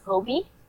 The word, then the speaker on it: Hobi: And Hobi, it's supposed to be pronounced Hobi.